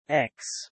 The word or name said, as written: ex-